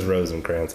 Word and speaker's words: Rosinkranz: She says Rosinkranz. I watched her Instagram.